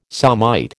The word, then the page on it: somite: American English